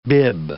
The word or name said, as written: bib